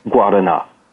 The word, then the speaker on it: Guarana: so so the way you pronounce it and the way you spell it are really different. So Guadana is spelled G U A R A N A and pronounced Guadana.